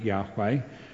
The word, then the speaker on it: Yahweh: We pronounce it Yahweh, and we'll come to that phrase in a minute.